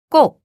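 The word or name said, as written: 나누다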